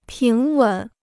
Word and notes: 平稳: píng wěn

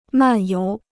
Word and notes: 漫游: màn yóu

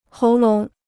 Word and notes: 喉咙: hóu lóng

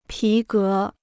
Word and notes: 皮革: pí gé

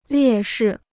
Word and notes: 劣势: liè shì

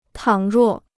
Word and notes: 倘若: tǎng ruò

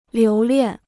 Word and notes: 留恋: liú liàn

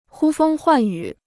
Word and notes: 呼风唤雨: hū fēng huàn yǔ

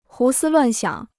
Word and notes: 胡思乱想: hú sī luàn xiǎng

